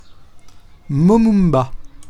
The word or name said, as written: momumba